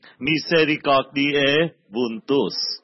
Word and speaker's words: Misericordiae vultus: Tiếng Latin đọc là Misericordiae vultus, thuộc lòng luôn. Misericordiae vultus, dung nhan lòng Chúa xót thương.